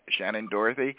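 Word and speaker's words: Shannen Doherty: Am I pronouncing her name Shannon Doherty? – Yeah.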